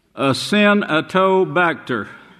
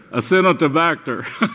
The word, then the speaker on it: Acinetobacter: hospitals called, get this name, Acinetobacter. Now, that's the way you pronounce it, but you pronounce it faster, Acinetobacter. But that's the way it's pronounced, A-Sin, S-I-N-E, then dash T-O, then Bacter. And that's exactly what it is.